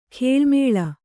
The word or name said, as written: ಗಾಳುಮೇಳು